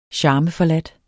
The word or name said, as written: charmeforladt